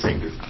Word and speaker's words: Oder: I mispronounced your name, didn't I? – It's Ader. – Ader. That German diphthong eludes me.